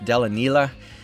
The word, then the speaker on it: Delanila: The band is Della Neela, and that's spelled D-E-L-A-N-I-L-A, sort of like vanilla, but pronounced Della Neela.